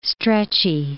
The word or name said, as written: stretchy